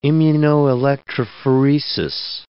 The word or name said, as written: immunoelectrophoresis